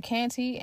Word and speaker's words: Kante: If you would like, you can also follow me on Facebook at Fonta Canty. And my name is spelled F-A-N-T-A, last name K-A-N-T-E.